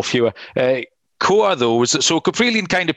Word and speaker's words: Kowar: The pitching matchup is Jackson, right, how are we pronouncing this? Kowar? Yeah. Jackson, K-O-W-A-R. Yeah. Jackson Kowar. Kowar.